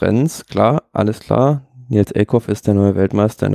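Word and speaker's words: Eekhoff: Eckhoff, ja.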